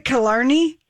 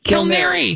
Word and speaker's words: Killarney: Am I saying it right? Killarney? – Kilnary. – Kilnary, for crying out loud.